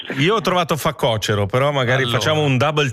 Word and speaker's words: facocero: Poi c'è una cosa che sono anni che non riesco a pronunciare bene, si dice facocero o facocero? – Questa è una domanda interessante perché io credo che tutti dicano facocero, ma in realtà si dica facocero.